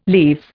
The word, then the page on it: leaves: Indian